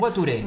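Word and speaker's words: voiture: Gracias, ¿baturé? ¿Cómo se pronuncia? – Guaturé.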